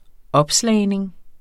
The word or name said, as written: opslagning